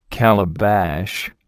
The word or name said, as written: calabash